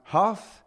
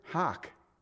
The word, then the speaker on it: hough: I've heard it pronounced hue. I've heard it pronounced ho. In the English, it's Old English, like the King James Version is. You know how you pronounce that word? Hock. It is. H-O-U-G-H. Hock.